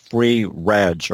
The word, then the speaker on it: FreeREG: One site that I had somehow overlooked for a long time is called Free Reg, or at least that's how I pronounce it, F-R-E-E-R-E-G.